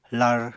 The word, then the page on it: lar: apatani